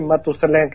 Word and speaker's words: Matusalén: O Matusalén, no me acuerdo cómo se dice, pero básicamente Matusalén en español.